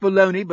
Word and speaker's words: bologna: Because baloney, it's pronounced baloney, but spelled Bologna.